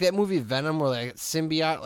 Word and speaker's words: symbiote: No, so Eddie Brock and this symbiote get together.